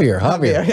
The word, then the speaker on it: Javier: Javier. Yes. Yeah, the J is silent.